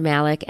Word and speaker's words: Malik: My last name is spelled Malik, M as in Mary, A-L-I-K, and I'm Asfa, and it's spelled A, S as in Sam, F as in Frank, A.